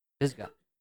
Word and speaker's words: Pisgah: How do you pronounce that, by the way? Pisgah? Pisgah.